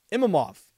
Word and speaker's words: Imavov: Nasruddin Imamov. Imavov. Wow, did I butcher that? Nasruddin Imavov.